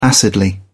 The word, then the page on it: acidly: British English